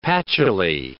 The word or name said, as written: patchouli